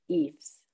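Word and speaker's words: Ephs: The Fs? Did I say that correctly? No? Okay. I saved that to the end because I'm just like, that's not how I'm going to. – So the guy's name was Ephraim Williams. – Ephraim Williams. Okay. – So they're the Eves.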